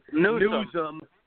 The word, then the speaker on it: Newsom: That Gavin Newsom guy, I mean, Newsman, however the hell you pronounce his last name. – Newsom, that guy's a joke.